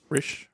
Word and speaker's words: Risch: Senator James Risch, I'm not sure how to pronounce his name. Risch. R-I-S-C-H. – Risch. – We're going to say Risch.